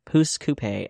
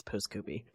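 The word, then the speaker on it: Pouce Coupe: And then it's like, there are two pronunciations. There's Pooscoopy and Pooscoupe. So then I was calling it Pooscoupe.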